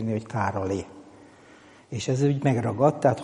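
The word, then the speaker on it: Károli: Úgyhogy ez a helyzet, csak mondom, az én szerintem a kiejtés az mindenképpen Károlyi, mert hogy akárhogy írjuk. Tehát nem fogunk ebből hitvitát csinálni, hogy most ott van ez az Y, vagy nincs ott van az Y, de a kiejtés az Károlyi.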